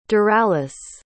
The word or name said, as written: daralis